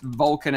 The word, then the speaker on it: Volkan: And the game is pronounced Vulcan as in V-O-L-C-A-N. Is that right? – I just put it in chat. So V-O-L-K-A-N is how you spell it.